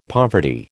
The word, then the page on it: poverty: American English